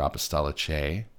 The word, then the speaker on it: Apostolicae: Acta Apostolica, or Apostolicae, I believe, as the Latinists pronounce it. I'm not one.